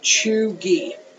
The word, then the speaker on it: cheugy: Okay. Chuggy. C H E U G Y. Pronounced Chuggy. Like, as in C H O O dash G E E.